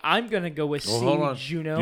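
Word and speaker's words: Juneau: If I had to guess, I would say Juno, Alaska has. – It rhymes with Uno.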